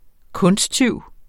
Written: kunsttyv

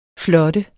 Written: flot

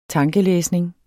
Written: tankelæsning

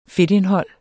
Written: fedtindhold

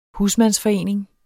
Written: husmandsforening

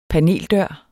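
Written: paneldør

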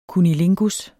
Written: cunnilingus

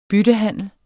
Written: BYTTEHANDELS

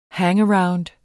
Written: hangaround

